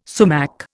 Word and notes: sumac: Indian

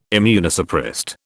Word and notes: immunosuppressed: British English

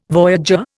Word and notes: voyageur: Indian

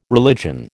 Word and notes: religion: American English

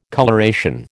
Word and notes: coloration: American English

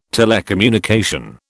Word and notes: telecommunication: British English

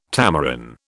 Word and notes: tamarin: British English

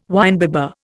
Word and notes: winebibber: Indian

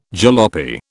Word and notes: jalopy: British English

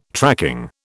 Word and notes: tracking: British English

